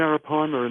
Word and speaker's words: Arapaima: That was that an Arapaima? How does that pronounce? – That was the Arapaima in the Amazon, which is said by many people to be the largest freshwater fish in the world.